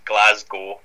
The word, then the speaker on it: Glasgow: So you kind of forget the W at the end, and it's like two words, Glasgow.